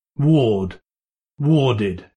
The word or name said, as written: ward